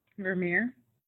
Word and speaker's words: Vermeer: Vermeer.